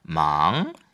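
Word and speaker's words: 망 (忙): 망. – 망. – 부드럽게 이성으로 발음했었죠. – 그렇죠. 네. 바쁘기 시작해. 이게 시작은 상승하는 거와 관련 있잖아요. 그래서 이성, 망.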